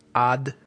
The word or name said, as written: ad